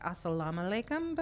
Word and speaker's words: assalamu alaikum: So I know we've covered like assalamu alaikum before. Mm. So there's like the extra O sound at the end. Assalamu alaikum. – Yeah. – Oh, okay. It's a little Tajiki twist.